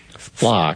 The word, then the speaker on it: Flock: is it plaque cameras? Is that how you pronounce it? – Well, yeah, they're automated license plate readers, but the brand that we have here in Sioux Falls and the Mineral County would be Flock.